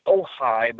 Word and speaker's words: Ojai: The town is Ojai, but it's spelled O-J-A-I, California. And you can kind of put in Ojai, that name, O-J-A-I, and it's vampire.